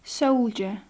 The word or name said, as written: soldier